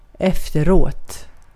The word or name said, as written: efteråt